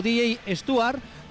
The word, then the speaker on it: Stewart: Lo he pronunciado como DJ Stewart porque ya está en marcha el servicio de pronunciación de la Liga CB. Y él lo pronuncia así, no dice DJ Stewart, sino dice Stewart. Así que yo me voy a quedar como lo dice el propio jugador, DJ Stewart.